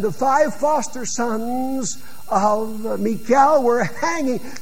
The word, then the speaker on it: Michal: Some pronounce her name Michael, but I pronounce it Michal so that you know it's a feminine name.